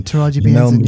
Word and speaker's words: Taraji P. Henson: Taraji P. Henson. – Yeah, Taraji P. Henson.